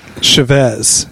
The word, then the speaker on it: Chavez: I believe it's... Chavez. – Okay. – Emphasis Chavez. On the first syllable.